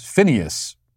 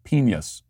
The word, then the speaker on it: penis: Another comment says, he mispronounced penis as Phineas. I can't. No, actually, I pronounced it as penis, which is the proper and dignified way to say the word.